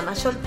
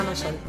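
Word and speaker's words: Mayol: Facebook, Lorena Maiol. Todo Lorena Maiol.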